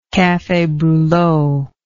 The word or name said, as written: cafe brulot